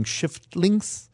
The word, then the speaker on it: Shiftlinks: There are a couple of game titles where you have to watch out that you pronounce them correctly, including Shiftlinks. Mind the F.